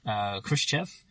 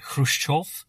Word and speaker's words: Khrushchev: Yeah, so the film goes with the pronunciation Khrushchev, but if you want to be really pedantic about it, it was something more like Khrushchev. They were sensible enough not to inflict that on the actors.